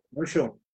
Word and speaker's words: Osho: Son los que están publicados y también está una sección de ay, no sé cómo se pronuncia, Oslo, Oso, Oso. – Osho.